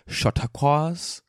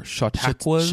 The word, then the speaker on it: Chautauqua: But he also wanted to put me on blast for not talking about Chautauquas. Or Chautauquas? – Chautauqua. It's pronounced Chautauqua. Chautauqua.